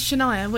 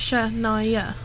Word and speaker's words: Shania: Yeah, know my name is Shania, which is three syllables. Shania.